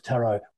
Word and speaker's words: tarot: And the French adaptation of that was tarot without the T sounded at the end.